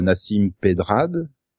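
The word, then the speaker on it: Nasim Pedrad: Euh, le quota fille est joué par Nassim Pedrad. Oui, si je prononce bien. Oui.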